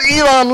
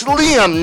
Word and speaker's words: Liam: Now, if you fast forward up to the 2000s, like about 10 years ago, it was Liam Neeson. All right? Liam Neeson is Hannibal Smith. – Elam Neeson. – Huh? – Elam Neeson. – It is not Elam Neeson. It's Liam Neeson. L-I-A-M.